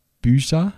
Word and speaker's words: Byza: Ähm, ähm, der Christian Bücher, so würde ich es jetzt äh, aussprechen, B-Y-Z-A.